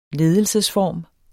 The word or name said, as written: ledelsesform